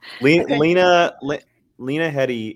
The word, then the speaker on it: Lena Headey: Lena Headey is, that's how you say her name.